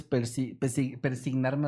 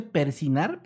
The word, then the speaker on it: persignar: ¿Cómo se dice persinar? Pero yo Persignar. pero la G casi no suena, ¿no?